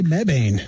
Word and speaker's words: Mebane: Why is it pronounced Mebane? How'd they arrive on that? – I say Mebane. – Mebane.